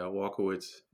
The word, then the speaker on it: Walkiewicz: Yeah, I think the original pronunciation was Walkiewicz. Was that like if a true Polish person would say it? But yeah, Walkiewicz is how the Americanized version.